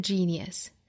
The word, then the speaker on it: genius: 我们来用美音读一下：Talent isn't genius, and no amount of energy can make it so. I want to be great or nothing. 首先，我们来看这句话有哪些发音要点。第一句话：Talent isn't genius. 这句话我们要注意。天才这个单词的发音 genius genius 这个单词其实包含三个音节，我们读的时候要读出这个层次感来 da da da genius genius，不要把后面两个音节混在一起读了，要读出两个节奏 genius genius genius。